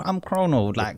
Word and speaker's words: Chrono: I'm Chrono, Chrono, Mr. RM Chrono, whatever you want to call me.